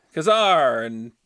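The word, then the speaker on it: Ka-Zar: And then, how do you say his name? Kazar! – Yeah, Kazar.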